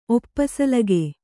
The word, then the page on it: ಒಪ್ಪಸಲಿಗೆ: kannada